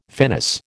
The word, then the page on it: finis: American English